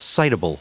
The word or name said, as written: citable